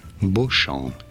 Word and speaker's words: Beauchamp: Beauchamp is how it is said in French. Beau, meaning beautiful, and champ, meaning field, as in a...